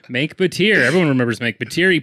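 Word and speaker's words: Mengke Bateer: Sorry, Menge Batir. – Menk. – Did I pronounce it wrong? – I thought we talked about this. – We did, but I thought I still had it right. – I said Menk like Frank.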